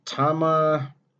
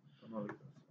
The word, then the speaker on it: Tamaulipas: Tijuana, those were the two main ones, but there's a state called, I don't even know how to pronounce that. What's it spell it? – It's Tamaulipas. – Spell it just so I know. – Tamaulipas. – Okay.